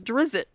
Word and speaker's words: Drizzt: So I'm assuming his name is Drizzt, right? – Is that how you pronounce it? All right, yeah.